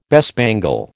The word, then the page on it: bespangle: American English